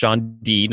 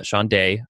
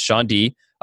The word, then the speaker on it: Chaundee: Chaundee Brown, and that is pronounced Chaundee, not Chaundee. I had that mistake and I looked it up. It's Chaundee.